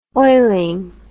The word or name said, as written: oiling